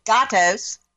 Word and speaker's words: Gatos: We've got Well Post, founder of Hound and Gatos. I wasn't pronouncing it in the beginning correctly. It's Gatos. It means cat in Spanish.